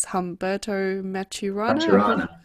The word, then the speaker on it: Humberto Maturana: Humberto Maturana? – Yes, indeed.